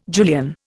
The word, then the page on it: Julian: Indian